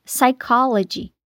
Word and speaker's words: psychology: Então já sabe, em palavras como pneumonia, em inglês, pneumonia, psicologia, em inglês, psychology, a letra P não se pronuncia.